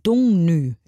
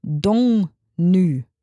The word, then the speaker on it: Dagny: Also sie wird ja geschrieben D-A-G-N-Y. Und mein deutsches Gehirn macht daraus ja Dagny, was einfach richtig krass falsch wäre, weil sie eben nicht Deutsche ist, sondern aus Norwegen kommt. Dongnü wird das hoffentlich ausgesprochen.